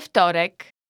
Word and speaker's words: wtorek: We wtorek.